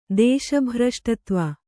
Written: ದೇಶಭ್ರಷ್ಟತ್ವ

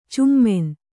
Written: ಛಮ್ಮನೆ

